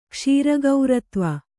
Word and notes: ಕ್ಷೀರಗೌರತ್ವ: kannada

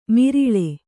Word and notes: ಮಿರಿಳೆ: miriḷe

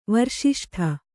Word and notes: ವರ್ಷಿಷ್ಠ: varṣiṣṭha

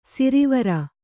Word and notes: ಸಿರಿವಾರ: sirivāra

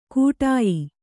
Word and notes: ಕೂಟಾಯಿ: kannada

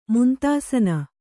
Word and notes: ಮುಂತಾಸನ: muntāsana

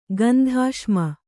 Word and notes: ಗಂಧಾಶ್ಮ: gandhāśma

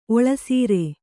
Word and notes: ಒಳಸಾರ: oḷasāra

